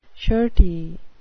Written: shirty

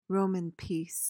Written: Roman peace

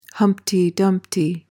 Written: humpty dumpty

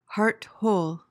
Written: heart-whole

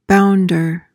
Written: bounder

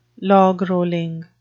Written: logrolling

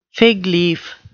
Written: fig leaf

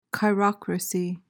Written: chirocracy

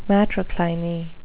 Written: matrocliny

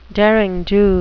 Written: derring-do

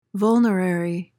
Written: vulnerary

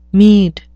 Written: meed

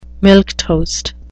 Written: milquetoast

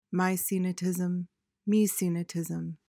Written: maecenatism

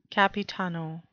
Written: capitano

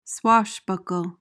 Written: swashbuckle

